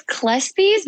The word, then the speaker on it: Kleespies: It's Kleespies, but you're really close. You're closer than most people.